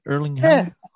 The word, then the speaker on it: Erling Haaland: Did I say it right? Erling Haaland? – Yeah, Haaland's fine. Yeah.